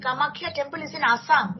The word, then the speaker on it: Kamakhya: Kamakhya temple. Kamakshi Amman, this is South India. But Kamakhya is the Bengali Assam.